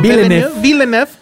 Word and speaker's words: Villeneuve: Bueno, la nueva película de Denis, nunca sé pronunciarlo, Villeneuve,